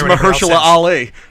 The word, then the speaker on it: Mahershala Ali: And so is Mahershala Ali.